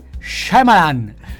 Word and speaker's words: Shyamalan: Allora la pronuncia è Shyamalan.